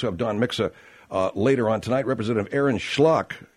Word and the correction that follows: Schock: Is that how you pronounce this guy's name? Schlock, seriously? – Yeah, I think so. Schlock. – Schock, sorry. Why would it be?